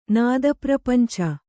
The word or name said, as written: ನಾದಪ್ರಪಂಚ